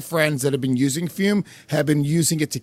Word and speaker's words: Fume: That is spelled F-U-M, pronounced Fume.